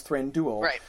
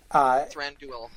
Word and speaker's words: Thranduil: But again, Tolkien has said the UI is pronounced like ruin. So when I pronounce Thranduil, Thranduil. – It's true that it's not like, you know, Thran-D-O-O-L. Like, you shouldn't pronounce it like that. – Drool? – Yeah, it shouldn't rhyme with drool.